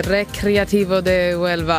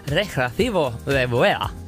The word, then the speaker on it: Recreativo de Huelva: Recreativo de Huelva. Alltså, Recreativo. – Med brasklappar. lite halvbra uttal. – Recreativo de Huelva. – Man vet ju det här med att det är tyst H.